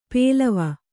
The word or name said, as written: ಪೇಲವ